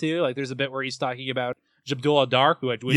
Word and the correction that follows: Kareem Abdul-Jabbar: Kareem Abdul-Jabbar.